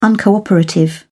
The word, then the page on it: uncooperative: British English